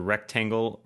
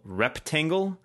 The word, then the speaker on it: rectangle: I had some crazy math teachers in high school, including one who insisted on pronouncing the word rectangle, reptangle.